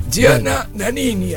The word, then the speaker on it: Gianna Nannini: Gianna Nannini, así se pronuncia.